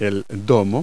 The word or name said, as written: dome